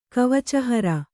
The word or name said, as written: ಕವಚಹರ